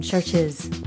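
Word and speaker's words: Chvrches: Just heard one from Churches, spelled with a V, not a U.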